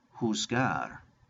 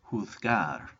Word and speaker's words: juzgar: Number five, juzgar. Or in Spain, this would be pronounced juzgar.